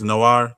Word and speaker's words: Noir: That's Black Movies Noir. That's how you say it? Like a noir film.